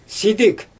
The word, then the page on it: sidɪg: Xyzyl